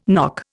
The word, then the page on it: knock: Indian